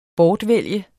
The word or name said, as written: BORTVALGT